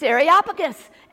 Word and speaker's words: Areopagus: It's Areopagus, Areopagus.